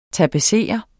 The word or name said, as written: tapetsere